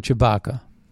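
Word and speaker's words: Tshibaka: Kelly Tubaka. Yeah, it's sort of rhymes with the guy from Star Wars, that Chewbacca. But there's a T in there and it's silent. Chewbacca, yeah. That's how you say it.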